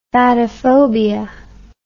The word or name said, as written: fatophobia